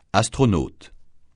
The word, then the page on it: astronaute: francais